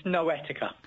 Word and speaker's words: Noetica: You're from a company called Noetica. I hope I pronounced that correctly. – It's Noetica. – Noetica. Ah. – Yes. – Near enough. Noetica. – It's all in the emphasis.